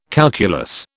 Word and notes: calculus: British English

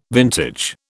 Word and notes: vintage: British English

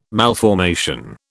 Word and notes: malformation: British English

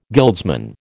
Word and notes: guildsman: American English

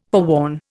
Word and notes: forewarn: Indian

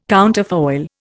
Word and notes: counterfoil: Indian

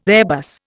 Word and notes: Airbus: Indian

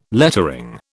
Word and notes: lettering: British English

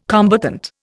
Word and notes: combatant: Indian

